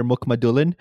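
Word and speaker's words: Mukhamadullin: So my absolute favorite pick of the first round was the New Jersey Devils taking was it Shakir Mukhmadulin? – yeah.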